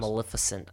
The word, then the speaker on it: Maleficent: Maleficent.